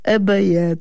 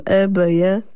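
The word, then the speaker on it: Ibeyi: Ebeye, to jeszcze raz powiem. Ebeye, a pisze się I-B-Y-I na końcu.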